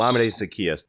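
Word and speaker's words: Olamide Zaccheaus: There's a guy Benjamin Solak has hyped a couple of times from Virginia here on the Kist and Solak show, the diminutive Alamide Zaccheaus. I think I'm saying that correctly. – Yes. – Alamide Zaccheaus.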